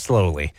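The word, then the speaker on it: Sloly: Like it's spelled like slowly? – No W, but otherwise, yeah. – Slowly. – Slowly. It's pronounced slowly.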